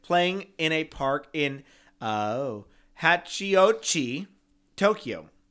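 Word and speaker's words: Hachiōji: Hachio with a long O, J-I. Hachioji. – Hachioji. – That's what I just said. – Hachioji.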